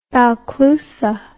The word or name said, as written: balclutha